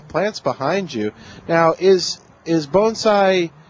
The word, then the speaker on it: bonsai: Yeah, I mentioned we were going to talk to some folks at the Bonsai Society, and I learned something right away, and that is you don't pronounce it that way. Why? You pronounce it Bonsai.